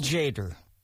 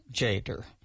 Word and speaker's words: Tjader: Cal Jader. And that's spelled T-J-A-D-E-R, Cal Jader.